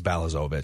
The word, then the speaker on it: Balazovic: Bozlavovic. I can never pronounce this guy's last name. – I think it's Balazovic. – Balazovic.